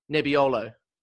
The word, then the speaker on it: Nebbiolo: Arguably, Italy's most famous red wine, the Barolo, is made from, now, how would I pronounce this bad boy? N-E-B-B-I-O-L-O. Nebbiolo. – Yeah, Nebbiolo. I'll go with that, mate.